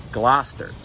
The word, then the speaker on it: Gloucester: Gloucester, because it also makes no fucking sense.